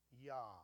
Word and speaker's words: Yah: And the second word is a contraction for the divine name. You would pronounce it Yah.